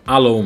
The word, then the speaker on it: Allo: entre elas dois novos aplicativos de comunicação um chamado Alo ou Alô, não sei qual é a pronúncia certa. Como é que é? Alô mesmo. Alô mesmo? – Tipo é. em português. É, tipo em português.